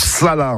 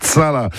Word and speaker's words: chala: Bref, une précision, en dauphinois, un Chala, ça se prononce Sala, en fait, Sala.